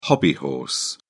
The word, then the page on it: hobbyhorse: British English